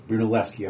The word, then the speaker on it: Brunelleschi: Brunelleschi, of course.